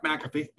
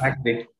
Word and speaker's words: McAfee: Yeah, Mark McAfee. Good enough. McAfee.